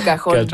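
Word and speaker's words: cajón: Et là, c'est ça, vous avez une contrebasse, un oud, le violon, le cajon, je ne sais pas si je le prononce comme il faut. – Le cajon.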